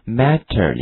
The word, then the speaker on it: matter: 好，同学注意一下，这个 matter 这个词哈，我们在美语中读得很夸张，我们讲过 T 在结尾的话读成 dirty 音，对不对？跟我读一下 matter. It doesn't matter.